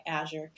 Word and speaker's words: Azure: but when you look at Azure, right? I'm saying it right, Azure.